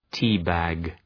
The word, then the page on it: tea bag: ‘ti:,bæg